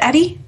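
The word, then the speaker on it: ED-E: And I am here with a dead Edie. See? – So you pronounce it Edie? – Eddie, sorry.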